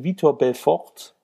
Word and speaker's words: Vitor Belfort: Du weißt, er wollte gerne gegen Vitor Belfort antreten. – Spricht man den so aus? – Ja, ich schon.